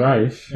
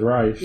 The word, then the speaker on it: Ra's: Ra's. – Yeah. – You're the one who told me that it was Ra's. – It is, but you... – You read it and you think Ra's because it's like, it's got the apostrophe. – See, I've written it R-A-I-S-H.